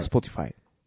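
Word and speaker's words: Spotify: スポティファイ。スポティファイ。読み方ってますはい、あと、はい、スポティファイ。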